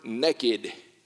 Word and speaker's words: naked: Now, Trevor makes fun of me with my Georgia enunciation, but I use the word naked. Now, Trevor uses N-A-K-E-D a little different. Southern folk pronounce it N-E-C-K-E-D, naked.